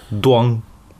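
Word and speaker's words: duang: Это читается Дуан.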